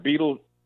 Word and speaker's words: Bidelshuis: Well, they didn't announce, and it's pronounced Bidelshuis. – Bidelshuis, okay. – Thinking of the last part being like cheese, and so you say cheese.